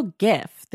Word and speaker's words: GIF: Oh, you go jiff with a hard J, like peanut butter. I go gift. This is like the age-long debate. – I know. I say GIF because the man who, you know, invented it says it's supposed to be GIF.